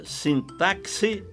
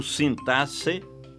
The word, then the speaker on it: sintaxe: Você pronuncia sintaxe ou sintasse? Que eu saiba, apenas o Aurélio abona ambas as formas. Prefiro acompanhar o Ais, Caldas Aulete e o Volpe, que registram apenas a pronúncia sintaxe.